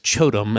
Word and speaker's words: QOTOM: But I found there's a Chinese vendor called Chotom. It's spelled Q-O-T-O-M.